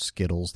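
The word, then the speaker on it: Skittlez: I think that's just pronounced Skittles. They just spelled it with Z's because they're cool.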